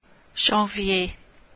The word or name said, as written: janvier